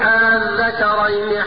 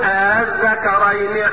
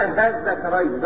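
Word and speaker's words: آلذكرين: تسهيل همزة الوصل يعني انطق بهمزة الوصل بحرف لا هو همزة ولا هو الف. يعني تسهيلها بحرف بين الهمزة والالف. وما يجيش بعض الناس يقولك انطقها هاء ده يبقى لحن ولحن جلي. لا اسمع مني على وجه التسهيل قل الذكرين حرم ام الانثيين. اسمع تاني قل الذكرين حرم ام الانثيين. لو عطيت النطق الغلط وتقول مش هو في الميكروفون قل الذكرين ده غلط.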